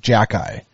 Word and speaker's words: Xhekaj: And his last name is spelled XHEKAJ. Mm-hmm. Which I've looked at it a million times, and in no way do I see how it spells out Jack-Eye.